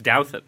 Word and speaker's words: Douthat: And it is Douthat. – Thanks for having me back. It's Douthat. – Because you have probably the most mispronounced prominent name in American life other than Alexandria Ocasio. – Ocasio-Cortez. – Yes. – Yeah. Did I get it right? Yes, Douthat is a fine old Northern English name that used to be, I think, Douthwaite, probably, with a D-O-W back in the mists of the 17th century.